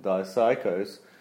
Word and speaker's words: dipsychos: Now, because the P is silent in psycho, we have to say it like it's supposed to be said, dipsychos, but I'm going to coin a new word for that type of person.